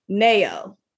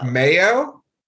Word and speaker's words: Nao: But anyway, so the artist I want to talk about, okay, I think it's, you pronounce her name, Nao. It's N-A-O. – Mayo? – I said Nao. Oh. N-A-O.